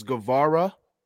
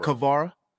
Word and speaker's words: Guevara: I just want to let you know that you every week on this show, you mispronounce his name is Guevara. – Guevara. – Not Kavara. Guevara.